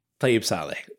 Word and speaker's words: Tayeb Salih: Tayeb Saleh? – Tayeb Saleh is how I would say it.